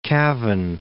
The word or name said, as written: Cavan